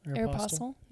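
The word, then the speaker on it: Aéropostale: Aeropostale, yeah.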